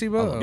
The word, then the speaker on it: Placebo: It's pronounced Placebo. – Yeah, it's kind of like the pill. Kind of like the water pills.